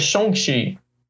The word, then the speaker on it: Shang-Chi: Shang estão falando que a pronúncia na verdade é Shang-Chi.